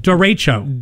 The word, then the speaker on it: derecho: How do you pronounce derecho? And that's so it's da sounds like a derecho. Derecho. – Derecho. It is derecho. Yes. We've been saying it wrong forever.